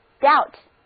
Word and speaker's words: doubt: 再来看这个单词 Doubt， D-O-U-B-T，字母组合 O-U 发 O 的音，字母 B 不发音。